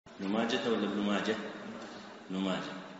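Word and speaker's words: ماجه: يا صاحي نطق ماجة بالها بلا لجاجة، يا صاحي نطق ماجة بالها بلا لجاجة، يعني كلمة ماجة تنطق بالها ولا يقال ماجة.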